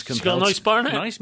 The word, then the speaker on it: Barnett: Now, this next one is a new single by Courtney Barnett, which is how you say her name. It's not Barnett, you say Barnett.